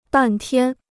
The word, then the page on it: 半天: bàn tiān